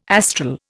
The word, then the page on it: astral: Indian